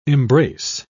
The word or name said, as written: embrace